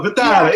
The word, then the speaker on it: Vitale: There you go, Vitale.